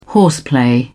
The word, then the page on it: horseplay: British English